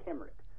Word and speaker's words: Cymric: Well, the second one is pronounced with a hard C, a K sound, Cymric.